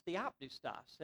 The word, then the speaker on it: theopneustos: First of all, the word pronounced in its original language is theopneustos.